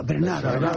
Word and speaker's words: Bernhardt: Tienes que pronunciar a la francesa, Bernhardt.